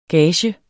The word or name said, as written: gage